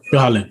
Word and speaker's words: Holland: Holland.